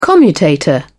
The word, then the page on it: commutator: British English